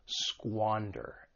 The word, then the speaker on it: squander: For pronunciation, the verb has two syllables. The stress is on that first syllable, squander. And keep in mind that S-Q-U-A has that squa sound, to squander something.